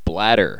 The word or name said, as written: bladder